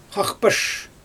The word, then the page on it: xaxpaʃ: Xyzyl